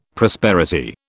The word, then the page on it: prosperity: British English